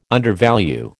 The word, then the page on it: undervalue: American English